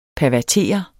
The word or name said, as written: pervertere